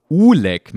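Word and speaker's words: Oelek: Sambal Oleg, in Klammern Aussprache Uleg mit U.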